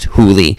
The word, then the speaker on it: Hole: and I can't imagine that that's the way it's supposed to be said. I think I went onto Wikipedia, and I looked it up, and I think it's pronounced Hooley. So when I see it in the book, I have to really remind myself not to call him Harry Hole, because then it would just distract me.